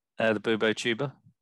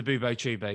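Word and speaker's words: Bubotuber: Yeah, specifically pus from the Bubo tuber. – The Bubo tuber, yeah, the Bubo tuber.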